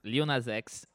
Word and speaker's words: Lil Nas X: Lil Nas X.